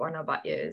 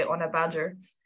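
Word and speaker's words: Ona Batlle: I think everyone listening to this podcast will be well aware of who Ona Batje is, or as the English say, Ona Badger.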